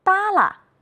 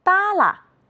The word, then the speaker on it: 耷拉: 扇子，是的，耷拉，竖着。你发现了什么？有的同学说，老师，后一个字都要读成轻声。你们真会观察，我们再来读一读。扇子，是的，耷拉，竖着。